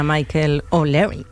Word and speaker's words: Michael O'Leary: Hombre, que se lo digan a Michael O'Leary.